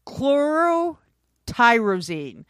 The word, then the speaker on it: chlorotyrosine: Chlorotyrosine. It's chlorotyrosine.